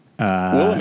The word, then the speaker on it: Willamette: Willamette. – I believe it's pronounced Willamette.